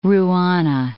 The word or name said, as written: ruana